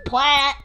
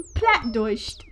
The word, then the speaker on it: Plattdeutsch: German low German, or low German, or low Saxon, or plat, pronounced plat. Plattdeutscht. It literally just means low German.